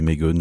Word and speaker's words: Magon: The Streets, c'était Magon, qu'on peut aussi prononcer Megon ou Magon, ou ce qu'on veut, M-A-G-O-N, en tout cas c'est le nom du groupe.